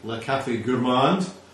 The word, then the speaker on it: Le Café Gourmand: I was in a cafe, Le Café Gourmand, that's the French pronunciation, earlier today.